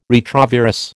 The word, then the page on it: retrovirus: American English